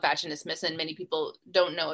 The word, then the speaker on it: vaginismus: So talk to us about your dissertation in vaginismus.